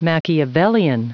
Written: machiavellian

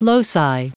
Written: loci

